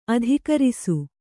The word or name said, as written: ಅಧಃಕರಿಸು